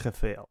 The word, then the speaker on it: Rafael: Come in portoghese, per esempio, non si dice Rafael, si dice... Come si dice? – Rafael.